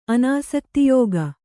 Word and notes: ಅನಾಸಕ್ತಿಯೋಗ: anāsaktiyōga

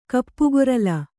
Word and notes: ಕಪ್ಪುಗೊರಲ: kappugorala

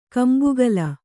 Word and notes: ಕೊಂಬೆಗಲ್ಲು: kombegallu

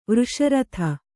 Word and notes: ವೃಷರಥ: vřsha ratha

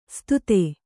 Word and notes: ಸ್ಥಿತ: sthita

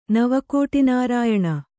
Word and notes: ನವಕೋಟಿನಾರಾಯಣ: nava kōṭiinārāyaṇa

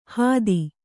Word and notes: ಹಾದಿ: Kannada

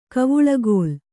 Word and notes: ಕವಳುಗೋಲ್: Kannada

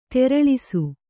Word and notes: ತೆರಳಿಸು: teraḷisu

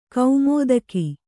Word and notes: ಕುಮುದಿಕೆ: kumudike

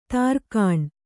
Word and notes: ದೊರೆಗಾಣ್: kannada

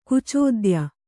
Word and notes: ಕುಚೋದ್ಯ: kucōdya

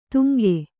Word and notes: ತುಂಗೆ: tuŋge

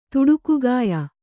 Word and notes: ತುಡುಕುಗಾಯ: Kannada

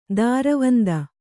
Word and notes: ದೂರವಂದೆ: dūravande

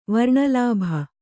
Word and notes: ವರ್ಣಲಾಭ: varṇa lābha

